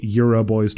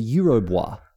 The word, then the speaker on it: Eurobois: It's a building called the Euro Bois. Right? – Oh, that makes a lot more sense. – And it's spelt Euro. – B-O-I-S, yeah. – B-O-I-S, right? And it's in French, it's the Eurobois. And I don't know what that means, but we were being like, Euroboys, right? – Euroboys, yeah.